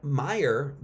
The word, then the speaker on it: Meyer: Well, it's not M-E-Y-E-R, it's actually M-A-Y-E-R. Now, Meyer, the name Meyer, you can pronounce both ways. You can pronounce it Mayer or Meyer, right?